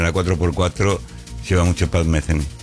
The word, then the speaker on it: Metheny: Metheny. – Pat Metheny. ¿Es un modelo de celular? – No. Se escribe TH y con Y. – Parece Pat. Metheny.